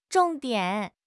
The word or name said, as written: chong2 dian3